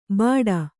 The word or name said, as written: ಬಾಡ